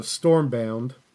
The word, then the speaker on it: Stormbound: I've been playing a ton of Stormbound.